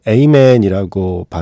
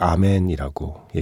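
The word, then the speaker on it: Amen: 보통 외국인들은 에이맨이라고 발음하는 거를 많이 들었는데 이 노래에서는 계속 아멘이라고 얘기를 하고 있네요. 아멘이었습니다.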